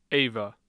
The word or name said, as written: ava